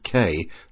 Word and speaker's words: Caye: R10N7A will be active as V31JP portable from Southwater K, that's spelled C-A-Y-E, in Belize, IOTA reference NA180, from the 31st of January to the 6th of February.